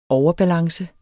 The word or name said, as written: overbalance